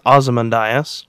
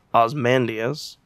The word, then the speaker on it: Ozymandias: That's Ozymandias. – That is what I meant. – Or also pronounced Ozymandias.